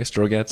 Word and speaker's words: Strogatz: Did I pronounce that right, by the way, Strogatz? – Yeah. – Yeah.